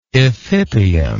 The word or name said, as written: ephippial